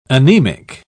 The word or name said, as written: anemic